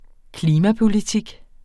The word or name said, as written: klimapolitik